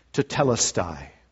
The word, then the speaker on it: Tetelestai: Tetelestai is how it's pronounced.